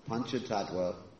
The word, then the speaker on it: Panchatattva: Panchatattva is not the same as Panchatantra. Sometimes people mispronounce that.